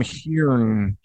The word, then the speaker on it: Heughan: I think it's pronounced Sam Heughan. Sam Heughan.